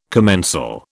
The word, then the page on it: commensal: British English